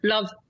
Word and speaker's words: LOVB: And Love is one. It's L-O-V-B, silent L-O-V-B. Sort of E, right, replaced with a V, right, which stands for League One Volleyball.